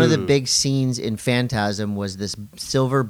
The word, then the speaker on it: Phantasm: Phantasm? Am I saying it right? – Phantasm.